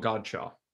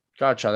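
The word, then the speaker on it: Godchaux: How do you say that name exactly correctly? Is it, did I say it right? – Devon Godshaw. – Godshaw, there you go. I know it's one of those Cajun names.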